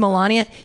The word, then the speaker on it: Melania: Is it Melania or Melania? We don't. It's Melania. Yeah, I don't know because she never speaks.